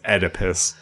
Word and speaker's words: Oedipus: I keep wanting to pronounce the O, but it's Oedipus.